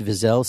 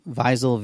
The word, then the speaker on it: Vizel: David Vizel, CEO. I hope I got the name right. Vizel, Vizel, V-I-Z-E-L.